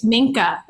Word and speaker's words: Minka: And mine, I'm going to tell everyone because it's Minka. That's her name. – Ooh, how do you spell it? M-I-K-A? M-I-N-K-A.